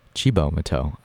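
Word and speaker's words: Cibo Matto: But up next, we're back with Chibo Mateau. I learned that's how it's pronounced in between songs because I looked it up on Google.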